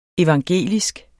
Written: evangelisk

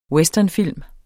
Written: westernfilm

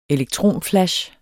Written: elektronflash

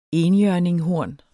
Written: enhjørninghorn

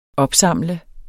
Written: opsamle

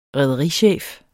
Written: rederichef

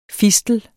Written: fistel

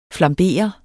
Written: flambere

